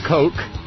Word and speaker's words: Koch: Nancy Pottenhauer had something to do with KOCH Industries, which I pronounced Koch because it's KOCH, but I am politely informed that it is pronounced Coke, which I should have known because that great English jurist whose name was spelled C-O-O-K-E was also pronounced Coke.